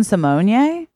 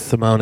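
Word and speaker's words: Symoné: That is technically how it's pronounced. – Don't say that. – It's true. – What? – It's true. And I actually learned that because she's a guest judge on RuPaul's Drag Race all the time. And RuPaul always says Raven Simonia, which is how it is pronounced. And she always goes, he's right.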